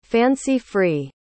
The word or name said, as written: fancy-free